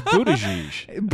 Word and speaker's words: Buttigieg: Bootleek? What? – No, you're way off. – Bootleek? – It's Buttigieg. – Buttigieg? Right? No, there's an L in there. – Mayor, there's no L.